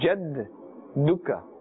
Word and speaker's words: جَدُّكَ: Whenever you see the shad, this symbol, that means that letter must be pronounced two times, right? So we are saying jadduka.